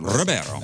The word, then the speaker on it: Ribeiro: Ribeiro.